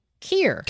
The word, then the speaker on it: Keir: And I'm sure I'll say his name wrong because I don't know how to pronounce K-E-I-R. Keir? Keir. Keir. – K-I-N-G-O. – K-E-I-R. – Oh, boy. K-I-N-G-O.